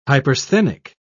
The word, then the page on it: hypersthenic: British English